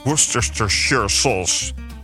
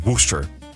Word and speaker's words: Worcestershire: Wat veel mensen uitspreken als Worcestershire sauce. De saus is ooit uitgevonden in Worcester, in het graafschap Worcestershire. Maar het is toch echt de bedoeling deze tongenbreker uit te spreken als Worcester.